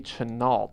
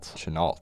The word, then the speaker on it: Chenault: Okay, so it's Claire Lee Chenault. – Chenault. – I believe is how it's pronounced.